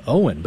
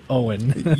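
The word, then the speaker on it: Eoghan: And he named him Owen, but guess how they spell it? – Oh, no. – E-O-G-H. – You're joking. – O-E-N. It's just like the most absurd Gaelic name. If you saw it on paper, you would not say Owen.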